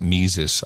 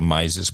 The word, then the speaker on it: Mises: And here's an example, the Mises, I think you pronounce it Mises. I used to say Mises, but I got corrected.